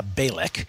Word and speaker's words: Bejlek: It's spelled like Sara Bejlek, but it's pronounced Sara Bejlek.